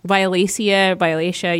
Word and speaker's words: Violacea: Sort of, but, you know, really, so the game is called Project Violacea, and Violacea, you can pronounce it either way.